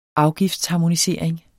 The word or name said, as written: afgiftsharmonisering